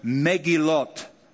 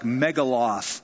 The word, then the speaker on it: Megillot: Megillot. Megillot. It looks like Megaloth. M-E-G-G-I-L-O-T-H. Megillot.